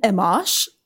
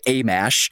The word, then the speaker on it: Amash: And I'm using it to ask you, what's a Justin Amash? – Okay, first of all, props for getting his name right. A lot of people say Amash, and he is a congressman from Michigan.